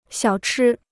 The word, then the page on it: 小吃: xiǎo chī